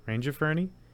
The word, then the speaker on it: Rangiferini: Soft G, hard G? – Rangiferini? Like range? – Yeah.